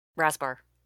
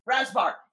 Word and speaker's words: Rasbar: Rasbar. – Rasbar. Yes, sorry. It's the two syllables.